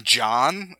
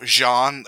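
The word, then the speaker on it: jawn: She pronounces it like the name John. Instead of Jean, it's a J-A-W-N. You gotta kind of drag it out, and you kind of, like, slur it and mumble it.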